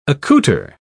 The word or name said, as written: accoutre